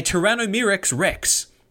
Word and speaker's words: Tyrannomyrmex rex: They found a Tyrannomyrmex rex. Now, I know that wasn't a mispronunciation.